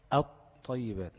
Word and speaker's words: الطيبات: تكتب ولا تنطق، يبقى كلمة الطيبات هتنطق الطيبات مش ال، الطيبات. عملت انا ايه؟ قلبت اللام لطاء وادغمت الطاء دي في الطاء دي، ماشي؟